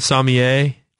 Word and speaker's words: sommelier: He's also just a really smart wine sommelier kind of guy. Am I saying that right? – It's just fine, yeah.